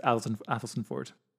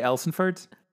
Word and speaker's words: Athelstaneford: I also just had to get Athelstan Ford out there because Athelstan Ford is spelt A-T-H-E-L-S-T-A-N-E-F-O-R-D. It's too many letters, too long a word. It's just pronounced Athelstan Ford.